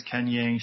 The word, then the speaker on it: Kenyang: T-N-O Shooting, and that's spelled T-H-Y-N-G-O Shooting on Instagram. – And mine is Ken Yang Shooting, K-E-N-Y-A-N-G.